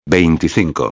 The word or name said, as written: 25